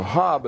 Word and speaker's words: Job: We've been in the book of Job, or Hob, as they say in Cuba, and that's the way to pronounce it.